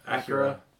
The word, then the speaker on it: Akira: I watched Akira. Is that how you say it? – Yeah. – No. Akira.